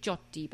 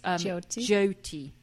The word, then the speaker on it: Jyoti: And the synopsis was described as, hang on, I've written down how to pronounce her name because I always say Jyoti, but it's not Jyoti. It's Jyoti. Jyoti.